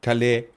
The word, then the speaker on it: Calais: wie heißt der Hafen? Calais? Calais? Calais, ja. Calais wird es ausgesprochen.